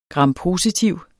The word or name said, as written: grampositiv